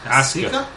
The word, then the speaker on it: Asuka: Asuka. – Asuka? – Asuka. Asuka. That's what you're saying. Asuka. – Asuka. – Asuka. – Asuka. – Asuka. – Asuka. – Yeah, Asuka.